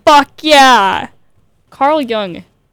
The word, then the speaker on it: Jung: Jung? – Young.